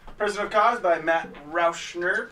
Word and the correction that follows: Rauscher: Rauscher. I don't think there's an N. – There's not an N. I am sorry, I've been saying it wrong this whole time.